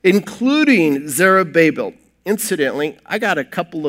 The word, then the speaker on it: Zerubbabel: So anyway, but it's Zerubbabel. That's how it's pronounced, just so you know.